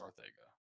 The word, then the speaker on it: Arcega: It's that part of his name is Spanish. It is Arcega.